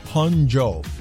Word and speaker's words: Hangzhou: This is Carl listening to East Coast Radio all the way from Hangzhou in mainland China. Loving it. Keep it up. Thanks, man. – So I get the China part, but it's the city. Hangzhou. I love how you pronounce it.